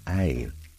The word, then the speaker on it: ail: Garlic in French is pronounced ail and spelled A-I-L. The word garlic derives from Old English, garlic, G-A-R-L-E-A-C, meaning gar, speak, and leek, as in a spear-shaped leek.